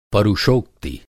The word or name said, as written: परुषोक्ति